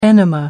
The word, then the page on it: enema: American English